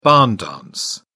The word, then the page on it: barn dance: British English